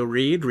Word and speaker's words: Reid: I'm on Twitter at Joe Reed. Reed is spelled R-E-I-D.